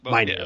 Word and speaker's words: Minear: It aired on September 20th of 2002 and was directed by Joss Whedon and written by Joss Whedon and Tim Minear. Is that how you pronounce it? – Minear, I think. – Minear, yeah.